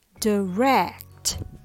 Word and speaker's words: direct: It's not completely wrong, but if you put the stress on the second syllable, it sounds better. So the rhythm goes ta ta, direct, instead of tada direct. The stress is on rect. So the first syllable, die, is reduced or becomes weaker and shorter.